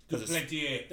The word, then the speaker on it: Duplantier: I'm sure I'm butchering his last name, but Duplantier. – That's all right. – Yeah, it's French.